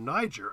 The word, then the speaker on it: Niger: Niger. And he got roundly criticized by the um, receptionist at the embassy, who said it should be pronounced Niger.